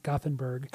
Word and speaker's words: Gothenburg: And oddly enough, they're from as we have learned, the city is pronounced Gothenburg.